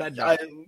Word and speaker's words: Bednar: Yep, but Bednar. To me, Bedrock. – Yeah. – Like the Flintstones.